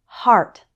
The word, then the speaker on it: heart: It's pronounced R like in car in the word heart.